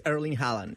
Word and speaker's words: Erling Haaland: No, this guy is Erling Haaland. – Erling Haaland.